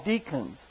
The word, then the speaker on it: decans: And the constellations that are close to them, within 8 degrees of the ecliptic, are called deacons. It's spelled D-E-C-A-N-S, but it sounds like a deacon, D-E-A-C-O-N.